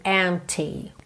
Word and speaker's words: empty: The days would all be empty. Empty, não se preocupe em pronunciar esse P, ok? Não precisa dizer empty, empty. Já fechou aqui o lábio no M? Já tá feito o P, ok?